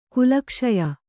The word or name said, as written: ಕುಲಕ್ಷಯ